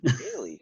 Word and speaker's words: Doelle: I'm here with Chris Doelle. – It's pronounced Daily, actually. – Daily. That's a weird way of spelling it. – Yeah, it's German.